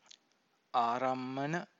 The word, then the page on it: arammana: Pali